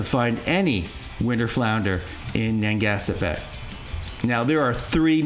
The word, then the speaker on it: Narragansett: The situation is similar in nearby coastal states, except in Rhode Island, where in Narragansett Bay, am I pronouncing that correctly? – Narragansett? – Narragansett, thank you.